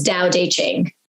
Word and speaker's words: Tao Te Ching: So I say Tao Te Ching. Some people like the T sound say Tao Te Ching, but I think the official pronunciation is Tao Te Ching.